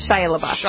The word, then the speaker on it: Shia LaBeouf: Shia LaBeouf. – Shia LaBeouf.